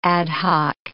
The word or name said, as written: ad hoc